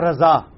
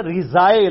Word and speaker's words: رضا: را زوئیں, زوئیں نہیں اس کو کہتے, زواد ہے عربی میں, بواد ہوگا. الف, اسی لیے آپ دیکھیں اسے کوئی رضا پڑھتا ہے, سپیلنگ بھی آپ دیکھیں انگلش میں غلط چل رہے ہیں. انگلش میں رضا کے سپیلنگ ہونا چاہیے آر آئی زیڈ اے, لیکن کیا لکھا جاتا ہے آر اے زیڈ اے, غلط چل رہے ہیں, لیکن اردو میں چل جاتا ہے.